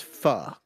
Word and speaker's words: pho: The correct pronunciation is pho, correct? – Yes.